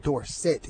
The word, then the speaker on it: Dorsett: I forget there was a fan of mine that says, "You know, your name is supposed to be pronounced Dorset." He said, "D O R and S E T T is set." That's it, set. So I said, okay, that sounds pretty good to me.